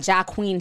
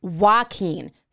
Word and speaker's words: Joaquin: So now I know it's not Jacques Queen Phoenix, it's Joaquin Phoenix.